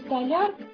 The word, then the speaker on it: столяр: А мой папа хоть и не столяр, но такие удобные полки сделал. – Правильно следует говорить «столяр». У столяра, столяры. – Запомните, в слове «столяр» ударение на «я», столярная мастерская, столярные работы.